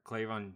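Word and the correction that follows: K'Lavon: I'm sorry if I'm messing up the name, but Kalavon Chaisson.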